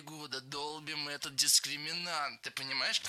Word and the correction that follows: долбим: Не долбим, а долбим. Ударение в этом глаголе всегда стоит за корнем. Долбишь, долбит, долбим, долбят. Во всех формах ударяем окончание.